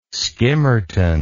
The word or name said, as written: skimmerton